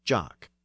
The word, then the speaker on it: Jock: The name also passed into Scots, which retained more of the original French pronunciation as Jock. And that Scottish word also passed back into Standard English as Jock, J-O-C-K.